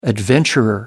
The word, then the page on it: adventurer: American English